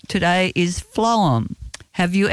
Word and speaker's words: phloem: Today we want to introduce you to an extremely important system in a plant, the phloem, and it's pronounced flow as in F-L-O-W-E-M.